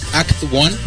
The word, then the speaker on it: Act 1: Act 1, así como Acto 1 en inglés, ACT 1.